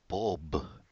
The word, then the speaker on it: Mould: Bob Mould. Yeah, it's Bob. That's how you pronounce it. I used to pronounce it Boob, but he corrected me.